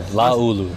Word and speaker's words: Laulu-Pututau: Laulu. – Laulu. Okay. Yep. As every tells me, that's pretty good. – Pronounce every vowel. Every vowel.